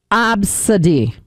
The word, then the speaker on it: ABCD: Like, someone's name is ABCD, but it's pronounced Absadi.